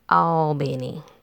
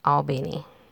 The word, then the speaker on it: Albany: Number two, we have a city that the name is spelled the same as the city in New York. Here, it's called Albany. Okay? Albany. So, just Vidalia, Albany.